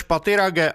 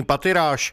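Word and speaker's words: Pathirage: Vyslovoval své jméno Rumeš Patirage a vyloučil anglickou výslovnost svého příjmení Patiráš.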